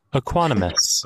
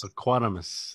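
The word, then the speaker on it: Equanimeous: Equanimous. – Equanimous.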